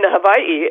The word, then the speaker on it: Hawaii: You know, my understanding is that with the Hawaiian language, that it, depending on the area you are, some pronounce the W as a V and some don't. But it's becoming much more and more common to pronounce it Hawaii.